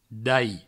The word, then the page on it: dalh: Provençal; dai